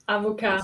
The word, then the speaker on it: avocat: Avocat.